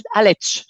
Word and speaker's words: Aletsch: Mais là aussi, je m'excuse pour la prononciation, mais c'est le glacier de euh, Jungfrau et puis celui de Altet, Alt, Aletsch. – Aletsch, Jungfrau et Aletsch.